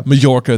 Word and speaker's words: Majorca: Jim Dale says it as Majorca. – Does he really? – I think so. – Okay. That's well. Then maybe it has double pronunciation. But I think Mallorca. It's in Spain and it's a soft J.